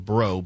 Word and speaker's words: Brough: Now, four women, including an Orange County supervisor, have accused Assemblyman William, I think he pronounces it Bro, B R O U G H, of Dana Point, of unwanted touching and other misconduct.